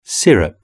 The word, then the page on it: syrup: British English